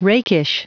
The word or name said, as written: rakish